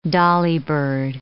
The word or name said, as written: dolly bird